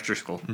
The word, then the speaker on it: Driskel: Yeah, Jeff Driskel.